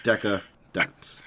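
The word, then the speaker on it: Decadance: It's actually pronounced, it was actually like shown Deca-Dance. D-E-N-C-E. Deca-Dance.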